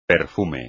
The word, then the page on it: perfume: espagnol